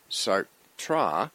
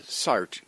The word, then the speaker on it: Sartre: French philosopher Jean-Paul Sartre, and with my wife's help, I checked on the internet, and actually some French sites are suggesting it be pronounced Sartre, but I think I'm going to go with the Sartre, just because that's what I grew up with.